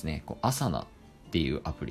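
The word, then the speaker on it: Asana: まあ、あの、アルファベットで A、 S、 A、 N、 A で、アサナと読みます。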